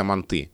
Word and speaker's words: манты: Где-то манты, где-то манты. То есть получается, что здесь нет единой нормы, единого ударения.